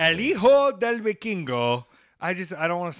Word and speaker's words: Hijo: You don't pronounce the H. – All right, so you say it. – Hijo? – Okay, so Hijo del Vikingo.